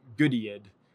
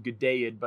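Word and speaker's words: Goodeid: I first learned that word wrong. I learned it Goodiad. and I think it's actually Gudeid, but by the time I learned that, it was too late.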